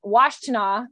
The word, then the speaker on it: Washtenaw: So since they were in, I'm going to say this wrong, Washtenaw.